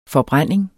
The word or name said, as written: forbrænding